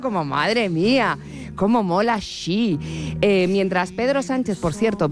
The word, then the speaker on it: Xi: Bueno, recordemos que el nombre de pila no es Chi. Rajoy nos recuerda cómo se pronuncia. – Xi.